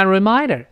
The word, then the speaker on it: remind her: When I when I 这么一个声音啊，when I remind her reminder，你看我没有动 remind her，remind 这最后是个的，那么这个 her 呢，h 后面是个 er，那么前辅后元，那当然就被打穿了。读成 reminder，reminder，when I reminder 这么一个声音啊。